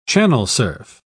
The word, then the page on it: channel-surf: British English